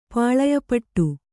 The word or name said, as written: ಪಾಳೆಯಪಟ್ಟು